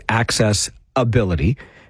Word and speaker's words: Access Ability: I always try to emphasize the name of the organization that way, Access Ability, because if you think of the word accessibility and you spell it correctly, it's a tough word, it's a tricky one, but it's one of those abilities that has an I in it, if you're spelling accessibility. But KW Access Ability is spelt with an A, Access Ability, hence the way I'm always emphasizing it.